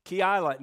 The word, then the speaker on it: Keilah: Keilah. Now, I know that's right because I have a book that says, how do you pronounce that? And it's in there.